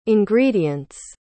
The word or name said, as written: ingredients